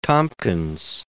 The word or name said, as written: Tompkins